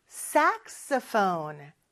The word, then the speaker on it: saxophone: saxophone. 이렇게 높낮이가 있어요. saxophone.